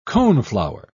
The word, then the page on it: coneflower: British English